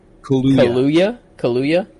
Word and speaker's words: Kaluuya: Jordan Peele and, how do you pronounce his last name? Daniel Kaluuya.